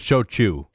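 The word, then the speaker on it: Shou Chew: She was questioning TikTok CEO Shou Chow, and I may be butchering the pronunciation of that name. – I think it's Shou Chu.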